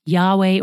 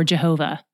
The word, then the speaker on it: Yahweh: Also, you may have noticed that when it said the Lord appeared, that was in all caps L-O-R-D, like we talked about on day 1. This is YHWH, God's personal name, often pronounced Yahweh or Jehovah.